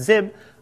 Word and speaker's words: XIB: So it's now the extension has been changed to XIB, so better pronounced zib, but they're still pronounced nib because they used to end in .nib.